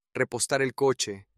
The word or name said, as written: repostar el coche